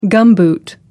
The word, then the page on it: gumboot: American English